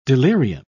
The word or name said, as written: delirium